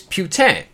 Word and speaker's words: Putin: Now, a lot of you are probably wondering, that's not how you say his name. It is Putin or Putin. You know, like, hey, that guy's Putin over there. No, believe it or not, I'm kind of a linguist. His last name is Putin. And if you listen to the podcast, I'm going to play a clip which explains why it is pronounced Putin.